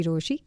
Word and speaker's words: Rochie: मेरा नाम छे मिर्ची रोशी.